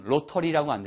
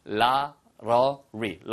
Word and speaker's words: lottery: 발음은 로터리라고 안 들리고요. 라러리, 라러리처럼 들립니다.